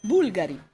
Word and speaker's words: Bulgari: So there's one that doesn't have a second to last stress syllable. – Yes. – Because it's Bulgari.